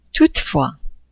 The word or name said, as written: toutefois